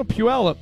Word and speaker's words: Puyallup: No, Puyallup. – Terrific. Puyallup, okay. – I learned how to say that because back in my days of covering the Idaho Vandals, they always had five views from Puyallup every single season.